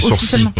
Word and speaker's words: sourcil: Et puis, il y a des mots sur lesquels on se plante toujours dans leur prononciation, comme sourcil. Alors ça, je savais pas. Le L est officiel. – On dit sourcil, ouais.